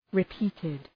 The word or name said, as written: repeated